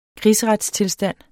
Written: krigsretstilstand

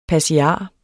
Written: passiar

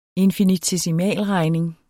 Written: infinitesimalregning

